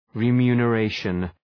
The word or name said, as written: remuneration